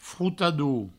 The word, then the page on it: frotador: froutadou